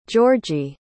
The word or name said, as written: georgi